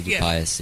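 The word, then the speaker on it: Dupuis: I also heard that that you were close friends with Sadie Dupais? Is that how you say her name? – Sadie Dupais, yeah.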